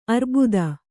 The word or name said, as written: ಅರಬೂತ